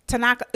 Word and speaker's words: Tanaka: The next nominee we have for this category, for best standalone episode, we have Odd Taxi, episode 4, Tanaka.